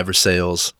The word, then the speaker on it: Versailles: So not Versailles, Versailles, Lafayette.